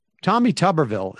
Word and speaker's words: Tuberville: I mean, I think like Tommy Tuberville, is that how we pronounce his name? – Yes.